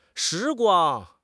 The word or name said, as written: 时光